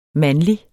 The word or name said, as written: MANDLIGE